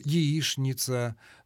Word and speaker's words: яичница: Ну, десяток, может быть, полтора слов, в которых требуется произношение «ш» на месте буквы «ч». Это «что» и его производные, «конечно». Это скучно. – Скучно. Ну, вот яичница, булочная. – Двоечник. – Ну, двоечник. Но булочная уже иногда произносит и так, и так.